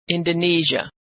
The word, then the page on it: indonesia: zh